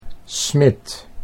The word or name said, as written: Smit